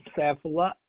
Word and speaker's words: Saifullah: It's Safula S. A-I-F-U-L-L-A-H.